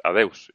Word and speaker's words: adeus: Y dice, nos dice adeus, en, que en gallego dice que se dice así, así que nada, pues, adeus.